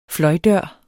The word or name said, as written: fløjdør